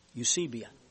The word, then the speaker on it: Eusebia: The word is spelled E-U-S-E-B-I-A. We pronounce it Eusebia, I think.